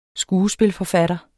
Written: skuespilforfatter

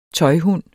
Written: tøjhund

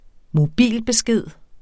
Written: mobilbesked

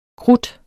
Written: krudt

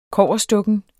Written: kobberstukken

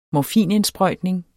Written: morfinindsprøjtning

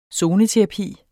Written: zoneterapi

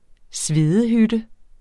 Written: svedehytte

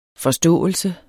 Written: forståelse

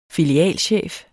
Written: filialchef

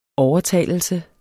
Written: overtalelse

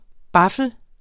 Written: baffel

